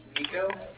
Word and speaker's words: Niko: My name's Nico, N-I-K-O.